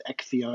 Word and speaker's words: Acciona: Yes, the accent and the pronunciation was perfect. It's Acciona.